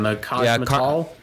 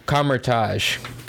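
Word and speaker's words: Kamar-Taj: The Cosmetal? – Kamertage.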